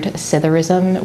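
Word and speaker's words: psithurism: But just sitting out there and learning to look at things and learning to sit, and I think there's a lovely word, citharism, which is the word for the sound of the wind blowing through the leaves of trees. Citharism, and it's got a silent P at the beginning.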